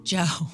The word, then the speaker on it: Djo: It's DJO, but it's just Joe. It's just pronounced Joe.